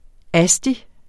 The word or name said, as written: asti